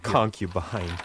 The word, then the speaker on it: concubine: Concubine. – Yeah. – Yeah, that's... – My wife found out. – It's in terrific shape. Mm-hmm. You pronounced it very well.